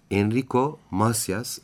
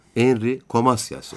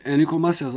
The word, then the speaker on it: Enrico Macias: Aslında Enrico Macias ama biz Türkler 70'lerde onu Henry Comasias olarak telaffuz ederdik ve öyle bilirdik. – O yanlışa çok düşüyorduk, evet. – Değil hatırlıyorum. – Henry Comasias diyebiliriz. Aslında Enrico Macias.